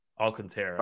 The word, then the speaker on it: Alcantara: I always say his name wrong. – Alcantara. Sorry. But yeah, whatever.